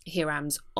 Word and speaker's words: Hiram: She's looking for Hiram's orrery. Now, it sounds like I'm not pronouncing a word correctly. – Can we just stop there? Hiram. – Hiram. – They call him Hiram.